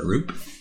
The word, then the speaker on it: Roope: I just realized I had been saying Rupert instead of Roop.